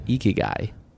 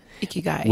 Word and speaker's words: Ikigai: So this book, I believe you pronounce it Ikigai. – Ikigai.